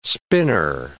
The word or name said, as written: spinner